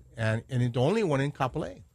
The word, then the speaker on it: Kapolei: So anyway, our guest today is Ilima at Lehano in Kapolei. Is that how you say it? In Kapolei? – Right. It's in Kapolei.